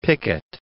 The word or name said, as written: Pickett